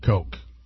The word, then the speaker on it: Koch: Of course, Coke is spelled C-O-K-O-C-H, Coke. Just the way that it's pronounced.